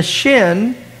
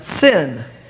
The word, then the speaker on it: shin: If the dot's on the left-hand side, it's pronounced sin. If the dot's on the right-hand side, it's sh, shin. If the dot's on the left-hand side, it's pronounced sin. It's an S sound rather than an sh sound.